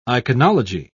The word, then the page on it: iconology: British English